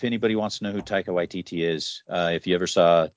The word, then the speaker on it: Waititi: Taika Waititi, yeah.